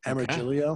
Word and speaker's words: Amargilio: So you could follow me at Amargilio. – Amargilio. How do you spell that? – A as in apple, M as in Mary, Ergilio. Amargilio.